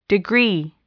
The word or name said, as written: degree